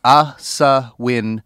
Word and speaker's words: Asawin: Aswin, last name, Sibsang.